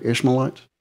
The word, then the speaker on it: Ishmaelites: Ishmaelites? – Yeah.